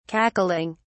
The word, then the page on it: cackling: British English